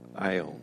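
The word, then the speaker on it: ion (αἰών): The first, he could use the word eon. It's spelled in Greek A-I, long O-N. We would pronounce that ion.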